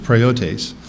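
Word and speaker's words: praotes: In Ephesians 4, verse 2, the word gentleness is translated from the Greek word praeotes, P-R-A-O-T-E-S. I've seen different spellings of it, but that's the one that Strong's uses, and that's how it's pronounced, praeotes.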